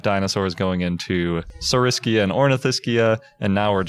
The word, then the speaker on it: Ornithischia: So we're joined, and he is the author of the somewhat controversial Ornithischia. Is that how you say it? – Ornithischia, yeah.